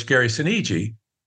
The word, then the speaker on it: Sinise: And I said, well, it's Gary Sinise.